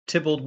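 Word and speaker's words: Theobald: Tybald Wolf Tone, as they say, pronounced it.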